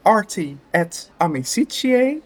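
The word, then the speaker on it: Arti et Amicitiae: En we zijn aan het rock-in bij de maatschappij Arti et Amicitie, zeg ik het goed? – Ja, helemaal goed.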